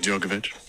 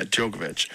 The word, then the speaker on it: Djokovic: Novak Djokovic. – Yeah, Djokovic.